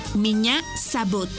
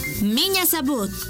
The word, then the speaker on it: меня зовут: Y se escribe P-R-I-V-I-E-T. Привет. ¡Muy bien! Repite conmigo. Привет. ¡Muy bien! En ruso, me llamo se dice меня зовут. ¿Y tu nombre? Una vez más, меня зовут. Por ejemplo, меня зовут. Esta palabra se escribe меня. M-I-N-A. Меня зовут. Z-A-V. U-T, sabut, miña sabut.